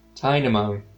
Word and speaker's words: Tynamo: And we will also be getting like a little electric eel Pokemon, Tynol, if I'm saying that right. – Tynamo. – Tynamo, yes.